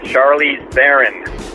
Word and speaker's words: Charlize Theron: It's Charlize Theron. Somebody give him the message so he can rant on me, because drives me nuts. I must have called in 10 times about this. Not Theron, it's Theron.